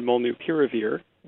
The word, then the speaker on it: molnupiravir: Do we call it the Merck pill, or do we actually call it by its name, which I believe is pronounced Molnupiravir? – What do you say? – So I use the work term molnupiravir, and I'll tell you why. Because the actual name is derived from Mjolnir, the Thor's hammer.